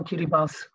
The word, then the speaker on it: Kiribati: Kiribati, das äh, ist äh, die Art, wie die I-Kiribati, die Bewohner von Kiribati, Kiribati aussprechen.